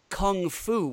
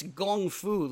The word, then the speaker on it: gong fu: So there is that, but then the fucking guy from Kunlun calls it kung fu with a K, which is a Britishism. It's gong fu, ladies and gentlemen. Anybody from fucking China is gonna pronounce it gong fu because that's the actual sound.